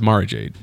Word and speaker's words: Mara Jade: I was really hoping she was going to be Mary Jade. – I thought it was Mara Jade. – Mara Jade. Well, Mara, you know. I'm Mexican. I pronounce things differently.